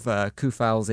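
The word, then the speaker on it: Coufal: Natural left back, he's come in at right back because of Koufal's injury, and he's held that spot. Now Soufal is kind of the two pronunciations of the same name differently there, Koufal and Soufal in one sentence.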